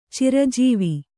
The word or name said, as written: ಚಿರಜೀವಿ